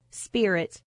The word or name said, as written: spirit